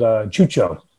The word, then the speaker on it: xuxo: I mean, when I was in Barcelona, there's a dessert called chucho. It's spelled XUXO.